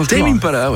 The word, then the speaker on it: Tame Impala: C'est sur Tame Impala, je le prononce correctement. – Tame Impala, ouais, c'est ça.